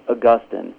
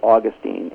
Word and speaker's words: Augustine: You know, some of the scholars insist that it must be pronounced Augustine, which I guess is maybe the British way of doing it. Others pronounce it Augustine, and actually either one's fine.